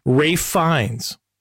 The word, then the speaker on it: Ralph Fiennes: It's spelled Ralph, but it's he's European, so he has to make things difficult. – Ralph by Ralph with a silent L.